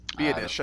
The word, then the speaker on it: Biadasz: Tyler Biadasz. Do we ever figure out that pronunciation? – Biadasz. I think you got it right there.